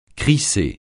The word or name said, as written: crisser